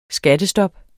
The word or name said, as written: skattestop